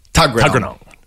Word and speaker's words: Tuggeranong: So the 5 for 44 must have been that because Jason Behrendorf played for Tuggeranong Valley Cricket Club. Am I pronouncing that correctly? – You could probably lose a couple of syllables there. Tuggeranong. That'll do.